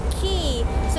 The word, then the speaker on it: Quay: คีย์สะกดหน้าตาแปลกๆนิดนึงนะ QUAY แต่คำนี้อ่านว่าคีย์เหมือนคีย์กุญแจละค่ะ